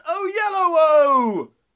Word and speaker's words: Oyelowo: But I say less YOLO, more David Oyelowo. It's David Oyelowo.